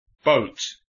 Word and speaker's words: boat: ¿No se dice boat, Craig? Se escribe B-O-A-T. Boat. – No. En inglés muchas veces las palabras no se pronuncian como se escriben.